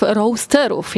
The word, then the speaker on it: roasterów: Razem ze mną Kacper Krzanowski, właściciel tego miejsca e, i dwóch baristów, roasterów, jak tutaj mnie pouczono przed chwilą. Dobra wymowa? – Jak najbardziej.